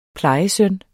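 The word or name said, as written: plejesøn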